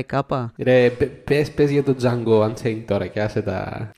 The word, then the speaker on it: Django: Ο Django, The D is Silent,